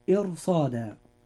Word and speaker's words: إرصاد: الراء في هذه الكلمة راء مفخمة لأنها جاءت ساكنة وما قبلها مكسور وبعدها حرف استعلاء، ولذلك ننطقها هكذا. – إرصاد.